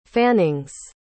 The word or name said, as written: fannings